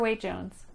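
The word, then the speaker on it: Waite-Jones: Asher Waite Jones.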